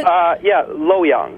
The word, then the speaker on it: Luoyang: and you've attended the peony festival in Luoyang. Is that how you pronounce it? – Luoyang.